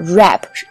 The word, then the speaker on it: wrap: 那它这个跟现在很流行那个嘻哈的 rap，r a p，发音是一模一样的，但是写法可完全不一样。 rap，r a p，这个包装纸的这个 wrap 前面有一个 w，是吧？